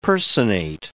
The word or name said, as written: personate